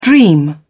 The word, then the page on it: dream: British English